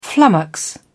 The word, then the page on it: flummox: British English